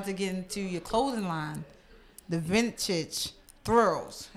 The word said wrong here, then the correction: Vintage Thrivals: Did I say that right? – Thrivals. Vintage Thrivals.